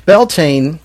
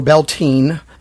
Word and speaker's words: Beltane: So Beltane, or Beltane, it's pronounced both ways.